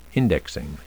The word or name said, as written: indexing